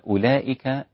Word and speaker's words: أولئك: أولئك على هدى، أولئك رسمت هكذا، هذه الواو رسمت لتبين أولئك من إليك، فلو رسمت إليك مع أولئك بدون نقط وبدون شكل ستجد نفس الرسم، فوضعت هذه الواو حتى تميز بين إليك وأولئك، ولكنها لا تنطق أبدا، وهذا هو سر رسمها في هذا المصحف بهذه الكيفية.